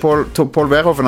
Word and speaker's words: Verhoeven: Paul Verhoeven, som jeg tror jeg er ikke helt sikker på om jeg uttaler navnet hans riktig, men det er sånn jeg uttaler det i hvert fall. – Jeg tror det er korrekt.